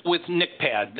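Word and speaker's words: NCHPAD: Amy, let's start with NICPAD. That's an interesting acronym. What does that mean? – It is. We added the H later, so it is silent.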